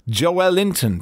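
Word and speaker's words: Joelinton: Tony Cowards, the comedian, said, why is no one pronouncing Joel Linton the accurate and much more fun way? Joel Linton, to rhyme with Wellington.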